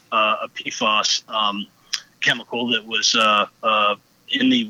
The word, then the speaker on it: PFAS: And the main forever chemical that they mentioned in the EPA report last week, and I'm probably going to mispronounce it, but the acronym is PFAS? – PFAS, yep.